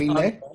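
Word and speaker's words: Aminé: So there's supposed to be an accent on the E there, so it's, I think Amine. Amine? – Like anime, but you're dyslexic.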